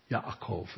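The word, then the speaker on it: Yaakov (יעקב): Yaakov is how the Hebrews would say it.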